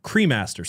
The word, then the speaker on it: Cremaster: And people have dug into what the hell this movie is and what Crewmaster means and all this stuff. So I'm saying it wrong. Is it Crewmaster or Cremaster? Cremaster, C-R-E Master, Cremaster.